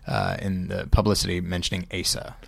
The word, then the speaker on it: Asa: How do you pronounce that kid's name? – I think Asa. Asa? – Yeah.